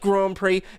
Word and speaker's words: Grand Prix: Like, it's Grand Prix, it's pronounced Grand Prix, Grand Prix.